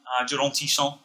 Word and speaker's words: Jérôme Tisson: Jérôme Tisson was exceptional. – Pronounced that very nicely, by the way. – Well, I mean, like, it's the correct pronunciation.